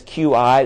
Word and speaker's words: qi: In TCM, it's that word up there that says QI. It's pronounced qi.